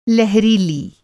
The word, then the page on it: लहरीली: لہریلی; Hindi